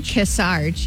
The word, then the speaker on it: Kearsarge: Kearsarge, yep.